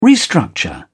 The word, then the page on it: restructure: British English